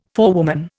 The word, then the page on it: forewoman: Indian